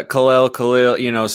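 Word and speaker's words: Kal-El: and the day that it was announced that Kalel, and is that the proper pronunciation? – Kalel, somewhere in that range, it kind of floats back and forth, but you got it.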